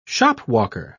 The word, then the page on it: shopwalker: British English